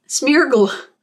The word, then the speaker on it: Smeargle: Smeagol or Smeargle? Smeargle? – Smeargle. – Yeah.